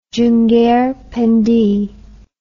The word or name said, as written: Junggar Pendi